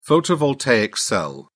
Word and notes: photovoltaic cell: British English

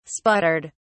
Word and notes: sputtered: British English

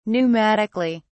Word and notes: pneumatically: British English

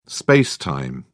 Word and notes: space-time: British English

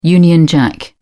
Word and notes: union jack: British English

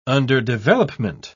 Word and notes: underdevelopment: British English

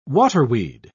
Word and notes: waterweed: British English

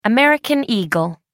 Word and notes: american eagle: American English

